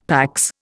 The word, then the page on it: PAX: Indian